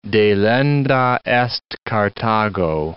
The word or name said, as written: delenda est Carthago